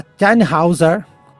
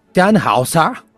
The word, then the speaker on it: Tannhäuser: Que se chama a Tannhäuser. – Chama isso de alemão? – Ah, desculpa. Tannhäuser.